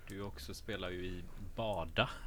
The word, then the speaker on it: BADA: det, är ju, alltså det är bara då, det, är, det är ju bada liksom. Ja, som att bada.